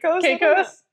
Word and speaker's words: Caicos: Just here, and Lila went to Turks and Cacao's. Is that how you say it? – Caicos? – Not, it's not chocolate.